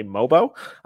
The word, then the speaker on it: Mogbo: So today we got Dalton Knecht from University of Tennessee and Jonathan, apparently Mogbo, spelled M-O-G-B-O. Apparently the G is silent.